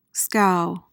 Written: scow

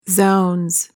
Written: zounds

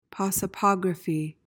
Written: prosopography

